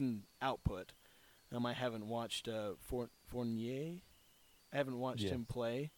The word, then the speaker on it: Fournier: Because if, like, you were to just do a swap, for, like, there's been rumors around Evan Fournier, I think that's how you say his name, from the Magic. – Fournier, okay.